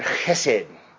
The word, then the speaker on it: chesed: If you're writing this down, it's spelled H-E-S-E-D or C-H-E-S-E-D, chesed. It's got this kind of throaty thing going on. I'm not Jewish enough to be able to get it right.